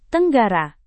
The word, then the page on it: tenggara: indonesian